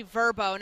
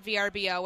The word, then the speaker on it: Vrbo: It's actually Verbo, not VRBO, which I said.